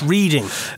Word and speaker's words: Reading: It's pronounced Reading. – Pronounced Reading.